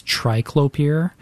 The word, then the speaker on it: triclopyr: And so what I'll recommend for this is a conventional herbicide that contains triclopyr. And I'm going to spell that for you. T-R-I-C-L-O-P-Y-R.